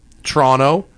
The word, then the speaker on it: Toronto: It's pronounced C-H-R-O-N-T-O. – Toronto. It's three syllables. – No, there's no T-O. It's Toronto. – Toronto. C-H-R-O-N-O. – You forgot the third syllable. – Where's that? – Toronto, eh? – It's pronounced like if you pronounce Chrono incorrectly.